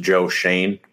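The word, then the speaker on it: Joe Schoen: It was built a lot with Brandon Bean coming over from the Carolina Panthers in 2017, who I believe it's pronounced Joe Shane, correct? Yep.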